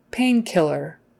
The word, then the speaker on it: painkiller: pain, A-I, pain, A-A, long A sound, pain, painkiller, killer, emphasis on the first syllable, painkiller, is when you say it all together, it's on the pain,